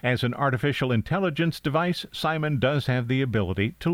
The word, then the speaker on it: CIMON: They shorten that to C-I-M-O-N, presumably pronounced like Simon.